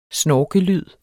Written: snorkelyd

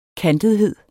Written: kantethed

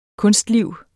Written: kunstliv

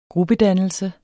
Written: gruppedannelse

